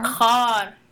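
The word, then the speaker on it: Kar (خار): But it was a song, is it Kahar or Kar? – Kar. – Yeah. – Yeah. That means thorn in Persian.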